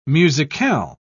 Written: musicale

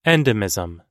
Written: endemism

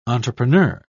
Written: entrepreneur

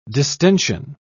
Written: distension

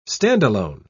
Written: standalone

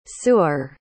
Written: suer

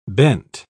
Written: bent

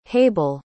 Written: hable

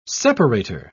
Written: separator

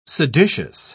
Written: seditious

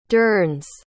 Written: durns

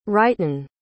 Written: ryton

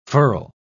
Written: furl

